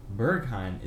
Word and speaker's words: Berghain: Berghain? – Berghain. – Berghain is unlike anything in the world.